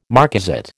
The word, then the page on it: marcasite: American English